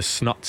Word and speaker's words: Snuts: I mean, you, one of your favourite songs, you can't even pronounce The name of the band correctly. – The West Lothian Boys, four of them in the band. – The Snuts. – Snuts, yeah.